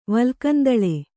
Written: ವಲ್ಕಂದಳೆ

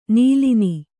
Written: ನೀಲಿನಿ

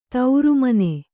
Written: ತಿರುಮಣ್ಣು

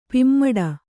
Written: ಪೆಮ್ಮಾಡಿ

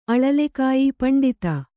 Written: ಪಂಡಿತೆ

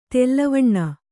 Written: ತೆಲ್ಲವಣ್ಣ

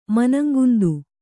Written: ಮನಂಗುಂದು

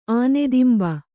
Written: ಆನೆದಿಂಬ

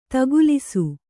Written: ದಾಖಲಿಸು

